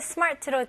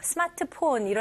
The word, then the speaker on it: smart: 근데 발음은 이름절로 하셔야 됩니다. 영어로 할 때는. 그래서 스마트 이렇게 하면 R 발음이 완전 생략됐잖아요. 여기서는 R 발음 해주셔야 돼요. 그래서 smart. 오케이? 여기서는 R 발음을 당기면서 힘을 주셔야 돼요.